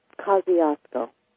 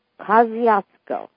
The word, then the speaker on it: Kosciuszko: Kosciuszko. – Kosciuszko.